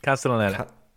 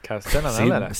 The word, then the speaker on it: Castellaneta: Castellaneta. – Castellaneta.